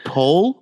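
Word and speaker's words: Pol: Dr. Poe. He's like Aussie or something like that. – It's Pole with an L? – Yeah, with an L.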